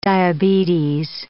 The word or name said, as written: diabetes